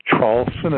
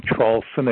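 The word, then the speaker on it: Traulsen: It's Hugh, H-U-G-H. The last name is Charlson. It's spelled T like Tom, R-A-U-L-S-E-N like Nancy. There's a big website. If you just put my last name, Charlson, again, T like Tom, R-A-U-L-S-E-N like Nancy, then the word and, A-N-D, and then Trump, T-R-U-M-P, and the word whistleblower.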